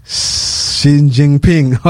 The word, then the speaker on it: Xi Jinping: Chinese president Xi Jinping.